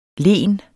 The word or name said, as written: len